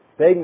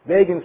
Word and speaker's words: Begin: Time magazine that week, you all remember, Begin rhymes with Fagin.